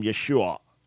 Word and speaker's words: Yeshua: The name would have been pronounced in its earliest form, Yeshua, with an ion, which I'm not saying clearly.